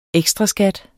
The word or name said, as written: ekstraskat